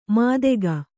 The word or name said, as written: ಮಾದೆಗ